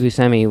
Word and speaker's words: Buscemi: I also think that Steve Buscemi was...